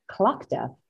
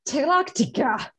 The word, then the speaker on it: Tlachtga: The sacred fire was supposed to be lit by the Druids at Clochta, which this name threw me because it's pronounced Clochta, but spelt T-L-A-C-H-T-G-A. – Yeah. – And I was like, Tilactica.